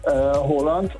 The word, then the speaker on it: Haaland: Er wird ausgesprochen Haaland, also mit O, ja. – Haaland. Und zwei A's werden als O ausgesprochen und ein A mit einem Kringel oben drauf ist eigentlich auch eine Aussprache, ein O.